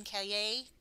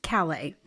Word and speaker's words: Kaye: I just read Making Rumors, the Ken Kaye book. – I should be able to say it because his daughter is a singer. Yes. Kaye. Yes, Kaye is how you say it.